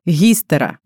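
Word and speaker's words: Гистера (ὑστέρα): Слово истерия происходит от греческого слова «матка», «гистера». Я на четверть гречанка, а мама наполовину, и вчера я маму мучила, как это правильно произносится. Гистера.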